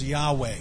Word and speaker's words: Yahweh: We really don't know in the original the way God's name Jehovah is pronounced, because in the original Hebrew there were no vowels. But the closest word or the closest pronunciation is Yahweh.